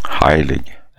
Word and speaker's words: Heilig: Hur man uttalar hans namn, det har jag aldrig förstått faktiskt. Svenskt eller tyskt uttal? – Heilig.